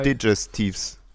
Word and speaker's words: Digestiv: Digestivs. – Genau. – Digestives. Wie ist die richtige ja. – Aussprache? – Digestiv. Digestiv. Digestiv, ja.